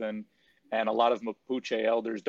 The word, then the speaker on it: Mapuche: Or Mapuche, is Mapuche the right way to say it? – Yeah, Mapuche, right.